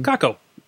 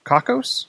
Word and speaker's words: Cockos: And what this does, and I don't even know how we should pronounce the company name. – Kako. – Kakos. COCKOS Incorporated.